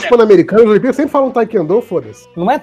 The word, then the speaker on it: taekwondo: É taekwondo a pronúncia correta da arte que a underline faz, e não Taekwondo. Tai, é, que o pessoal fala não, Taekwondo.